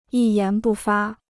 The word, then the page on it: 一言不发: yī yán bù fā